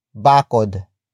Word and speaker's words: bakod: Bakuran comes from the root word bakod.